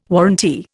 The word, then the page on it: warrantee: Indian